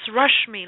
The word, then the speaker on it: Rashmi: Yeah, it's Rashmi, like rush me, don't rush me.